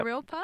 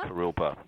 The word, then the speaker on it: Kurilpa: you were recently involved in action within the community against some anti homeless uh, infrastructure that was going around um, under the Kurilipa Bridge, if I pronounce Kurilpa. that right. Yeah. Kurilpa. Kurilpa.